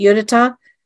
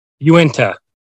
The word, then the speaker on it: Uinta: Uinta? – Uinta.